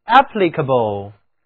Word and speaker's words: applicable: C-A-B-L-E 在这里不是读作 cable，而是 cable， cable。字母 A 读倒写的元音 a，请跟我来读 applicable， applicable。